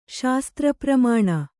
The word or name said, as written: ಶಾಸ್ತ್ರಪ್ರಮಾಣ